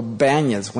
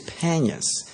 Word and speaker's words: Panias: The people of the land can't pronounce B, so they always go Banias, when instead of a P, it's Panias.